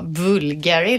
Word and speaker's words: Bulgari: Jag tror faktiskt man säger Bulgari. Bu ja, för det gjorde jag från början. Då var det någon som sa, nej det ska vara Bulgari, liksom.